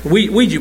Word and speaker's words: Ouija: Have you ever heard of a squeegee board? And you say, "Oh, it's a harmless game." No, it's not. It's not. Squeegee board. Ouija board, excuse me. Ouija board. Well, it is spelled a Q, so.